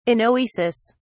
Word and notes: anoesis: British English

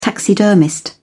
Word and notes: taxidermist: British English

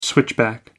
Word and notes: switchback: American English

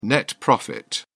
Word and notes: net profit: British English

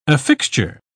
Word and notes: affixture: British English

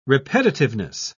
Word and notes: repetitiveness: British English

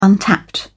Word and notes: untapped: British English

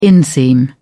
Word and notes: inseam: British English

